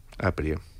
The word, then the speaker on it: Aprie: Aprie. – Con acento en la primera, en sí.